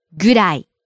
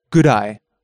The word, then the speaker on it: ぐらい: ぐらい. – ぐ, ぐ, ぐ. – ぐらい. – So, it should be pronounced as ぐらい. When it is preceded by a quantity such as time, price, or weight.